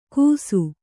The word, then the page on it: ಗೂಸು: gūsu